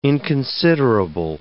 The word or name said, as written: inconsiderable